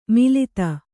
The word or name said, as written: ಮೆಲ್ಲಿದು